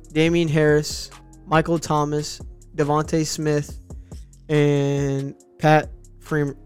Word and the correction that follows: Freiermuth: Pat Freiermuth. – Freiermuth, yeah.